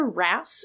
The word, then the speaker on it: wrasse: These are called cleaner wrasse. It's spelled WRASSE, but pronounced wrasse.